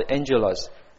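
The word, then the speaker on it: Angelos (ἄγγελος): 那在希腊文呢，这个好，这个英文念好是 Angelos，很像 Angel 哈，很像 Angel。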